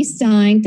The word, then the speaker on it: Stein: Okay, we're back with It's a Dog's Life, and I'm talking to the brilliant Dr. Emily Stein.